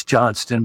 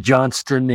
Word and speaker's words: Johnston: I always want to say Johnson because that's just natural. Can you pronounce it for our listeners? – Well, it's Johnston, but I've been confused with the big unit many times in many different cities.